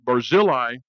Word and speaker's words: Barzillai: And then we find this guy mentioned 10 times total, but 8 times we'll look at tonight, Barzillai, the Gileadite of Rogalim. And I am pronouncing that like Hebrew.